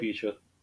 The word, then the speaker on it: alopecia: Alopecia.